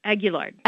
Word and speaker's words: Aguillard: And the reason this was important is that there was the Aguiar, how do you say that? – Aguillard.